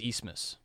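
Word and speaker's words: Abmas: He has a weird last name that is not pronounced the way that it is spelled. But yeah, he's A-B-M-A-S. – It's Eastmas. They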